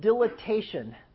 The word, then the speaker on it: dilatation: Now, if there's strictures, we can do what's called dilatation. How is dilatation different than dilation? Yes, it does have an extra syllable.